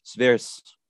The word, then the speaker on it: Zwirs: My last name is Zwirs.